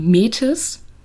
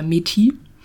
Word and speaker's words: Métis: Dann gibt es noch die Métis oder Métis. Französisch ausgesprochen.